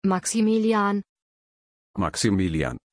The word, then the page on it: Maximilian: German